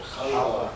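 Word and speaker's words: halva: There is a bar, or candy bar, I guess it's called halava, which comes from, how am I pronouncing it? – Halava. – Oh. Thank you.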